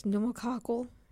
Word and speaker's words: pneumococcal: Do you know how to pronounce that one? – It's pneumococcal.